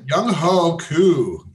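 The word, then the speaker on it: Younghoe Koo: Young Wei Ku? Is that the points. – pronunciation? Young Ho Koo, yeah.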